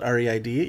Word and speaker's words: Reid: Sure, you can go onto Twitter and find me at Joe Reid. Reid is spelled R E I D.